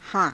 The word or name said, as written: haa~